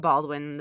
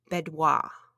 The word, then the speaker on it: Baudoin: And they all follow either they're not Baldwin, the books. They're I can do this. I looked it up. It's Bedwah. – Bedwah. – Bedwah. It's spelled Baudoin.